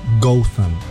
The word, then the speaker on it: Gotham: Y que en inglés británico el pueblo se pronuncia algo así como Gotham.